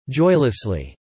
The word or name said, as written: joylessly